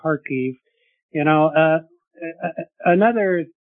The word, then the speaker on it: Kharkiv: And when I say Kharkov, that is how it is pronounced, Russian. Kharkiv is Ukrainian.